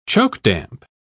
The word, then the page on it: chokedamp: British English